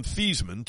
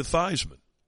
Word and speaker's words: Theismann: So he convinced Joe to change his name pronunciation from Theismann to Theismann. Theismann for the Heisman.